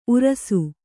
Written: ಊರಾಸೆ

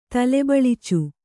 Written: ತಲೆಬಳಚು

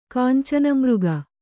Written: ಕಾಂಚನಮೃಗ

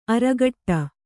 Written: ಅರಘಟ್ಟ

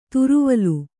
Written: ತ್ರಿವಲಿ

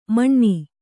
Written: ಮಣ್ಣಿ

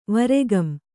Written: ವರೆಗಂ

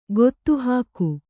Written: ಗೊತ್ತುಹಾಕು

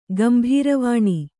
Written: ಗಂಭೀರವಾಣಿ

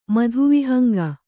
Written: ಮಧುವಿಹಂಗ

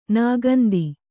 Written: ನಾಗೊಂದಿ

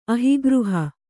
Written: ಅಹಿಗೃಹ

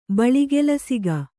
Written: ಬಳಿಗೆಲಸಿಗ

